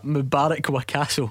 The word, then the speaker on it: Mubarak Wakaso: McCadley Wibasu. – Uh, um, wow, you butchered that. Mubarak Wakaso. Aye, that's right.